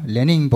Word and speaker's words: Lenín: Vos fijate que hasta Lenín Moreno, Lenín porque él lleva acento en el caso de hasta Lenín Moreno, está rompiendo con Correa y planteando retrocesos en algunas cuestiones, ¿no?